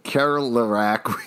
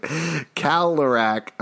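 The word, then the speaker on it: Kerlerec: I live on Kerlerac Street. I know Alex just said Kerlerac. We actually pronounce it Kalerac. Oh, wow. This is getting in my head here, Brett. I don't know why no one pronounces the R, but that's just how it is.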